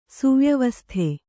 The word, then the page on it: ಸುವ್ಯವಸ್ಥೆ: kannada